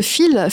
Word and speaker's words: Pheal: Euh, alors, je voulais qu'on revienne sur euh, euh, la création de fil. Fil, mhm. déjà, qu'est-ce que ça signifie Pourquoi fil Ça s'écrit PH. – Euh, Oui. Oui, c'est ça.